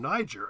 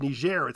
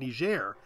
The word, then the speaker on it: Niger: Niger. And he got roundly criticized by the um, receptionist at the embassy, who said it should be pronounced Niger. It's my country, we pronounce it Niger.